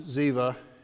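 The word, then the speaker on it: Ziva (צִיבָא): And it goes on, now there was a servant of the house of Saul whose name was Ziba. Probably actually pronounced Ziva in Hebrew.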